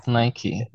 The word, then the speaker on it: Nike: Nike. Yeah. – Great. – Like usually we never pronounce E on the end, but in this word we should pronounce.